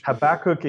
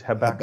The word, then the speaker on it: Habakkuk: Habakkuk in the English, Habakkuk.